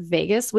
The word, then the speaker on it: vagus: It's either in the top under courses, or you could just type in the word Vegas, which is different than the city. This is spelled V-A-G-U-S.